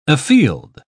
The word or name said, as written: afield